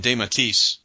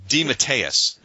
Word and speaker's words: DeMatteis: Now, Giffen and DeMatteis. See, I say it wrong all the time. DeMatteis.